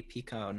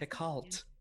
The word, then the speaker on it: Picoult: So did I say her last name right? – You did. Congratulations. – Like Pico de Gallo. – Yes, exactly. But spelled with an L and a T. O-U-L. – Picoult. – Uh-huh. Most people say Jodi Picoult, but it's not.